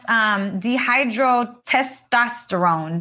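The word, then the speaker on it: dihydrotestosterone: And that's dehydrotestosterone, DHT. Did I say that right? – Yes, you did.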